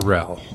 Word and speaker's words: Terrell: Is the emphasis on the first syllable or second? Is it Terrell or Tyrell? – It's Terrell in the first syllable. – Terrell.